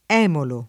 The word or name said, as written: emulo